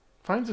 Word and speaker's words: Fiennes: Fiennes is right.